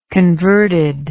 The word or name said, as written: converted